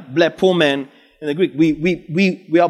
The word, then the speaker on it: blepomen: The word there is blepomen in the Greek. We see Jesus. Blepomen, to observe, to be watchful of, to discover by use. Blepomen. We blepomen Jesus, spelled how it's pronounced, B-L-E-P-O-M-E-N. We observe Jesus.